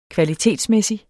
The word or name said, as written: kvalitetsmæssig